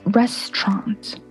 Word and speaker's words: restaurant: And so the correct pronunciation is restaurant.